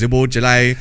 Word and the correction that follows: Szoboszlai: Okay, his name is Dominic Szoboszlai.